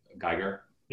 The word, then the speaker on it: Giger: Uh, Giger or Geiger, how you pronounce his last name. That Geiger? Yeah, the latter.